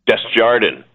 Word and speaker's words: Desjardin: Desjardin.